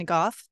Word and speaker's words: Gough: which is H-U-F-F. The spelling is different, but my pronunciation and my last name is close enough that I write under Tanya Karen Gough so that people don't get confused.